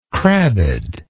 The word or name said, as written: crabbedness